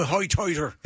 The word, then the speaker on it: Hoitoider: I'm a hoitoiter. Try to sit out there in my port. – It's spelled H-O-I-T-O-I-D-E-R.